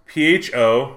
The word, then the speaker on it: pho: So it's spelled P H O, which is pronounced pho, and then Keene, since it's in Keene, New Hampshire.